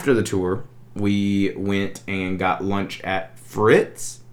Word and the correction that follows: Fitz: Is that how you say it? – Fitz. Yeah.